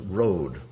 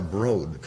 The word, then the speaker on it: Broad: The Frick is at the lower right, and the newest of this genre, the Eli Broad, rhymes with Broad, the Eli Broad collection. You can't say Broad.